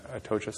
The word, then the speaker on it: Atocha: I really enjoyed Ben Lerner's book, I guess that's fairly well known, but maybe, Leaving the Atocha Station. Am I pronouncing that right? – I think that's right. That's how I was going to pronounce it.